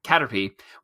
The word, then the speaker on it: Caterpie: Ash says goodbye to his Butterfree, and we see a montage of Ash capturing Caterpie. Is that how that one's pronounced? – Caterpie, which then evolved into a Metapod, which then evolved into Butterfree.